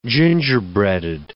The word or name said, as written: gingerbreaded